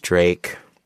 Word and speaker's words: Drake: His name is Drake. Rhymes with fake.